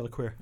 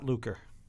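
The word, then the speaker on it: Luquer: Yeah, LaQuire. – I don't think that's true, by the way. – It's not Lucre.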